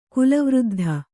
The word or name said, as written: ಕುಲವೃದ್ಧ